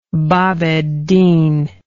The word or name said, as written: bab eddin